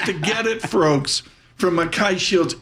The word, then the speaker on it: Mackay: Eric Kozatsky joins us with Mackay Shields. Am I pronouncing that right? – You got it. – Mackay, I nailed it.